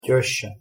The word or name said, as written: tesca